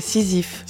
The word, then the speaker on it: Sisyphe: Oui, est-ce que je le prononce bien ? – C'est ça, Sisyphe.